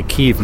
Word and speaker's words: Kyiv: You have to say Kyiv now. You can't say Kiev. They changed the pronunciation.